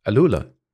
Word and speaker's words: AlUla: Yeah, so we drove through there and we did do the drive to Alula, which I know I that is a tough one to pronounce. – You, but, but you, you got it just right. Alula.